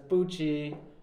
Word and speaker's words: Fuqi: It's Fuqi. Rhymes with Gucci and I'm too broke to afford Gucci, sadly.